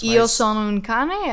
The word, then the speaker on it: Io Sono Un Cane: I really got into this artist, this Italian artist named Io Sonancane. I don't know if I'm saying that right. That's Io sono un cane, as in like I am a dog? – Maybe.